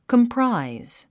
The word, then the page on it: comprise: kəmpráiz; コンプライズ